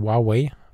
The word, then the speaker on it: Huawei: Also mit so einem Huawei, also Huawei, korrekt ausgesprochen, Telefon, unterwegs gewesen und habe natürlich nicht so fotografiert wie vor vier Jahren in Mittelamerika.